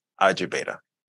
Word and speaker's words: Ajibeta: It's Ajibeta, like Alpha Beta, but Ajibeta.